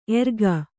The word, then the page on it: ಎರಗು: eragu